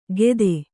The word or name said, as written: ಗೆದೆ